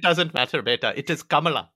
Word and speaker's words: Kamala: And also, wait, I'm pretty sure it's Kamala Khan. I think that's how they pronounce the name in the comics.